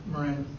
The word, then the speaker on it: Moran: Is that the right way to say that, Morin? – Moran. – Okay, actually, okay. I would have said Moran, but I thought you're an Australian, you would know. Okay.